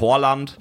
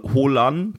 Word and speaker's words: Haaland: Nämlich nicht Haaland, nicht Horland. Ähm, die, es gäbe auch noch die Alternative, Holan zu sagen.